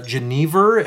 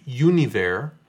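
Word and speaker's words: genever: We have, I think we maybe made one, but this is with Geneva, as it is pronounced in English. If we were pronouncing it the Dutch way, it would be Univer, which is the Dutch word for juniper.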